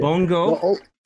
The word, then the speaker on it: bongo: Bongo. Yeah. All the O's are pronounced long O.